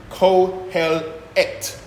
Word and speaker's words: Qoheleth: And you see that word, Q-O-H-E-L-E-T-H? You actually pronounce it Kohelet, yeah?